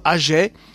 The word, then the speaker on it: Agyei: dont le compositeur est Agiei, A-G-Y-E-I, prononcé Agé.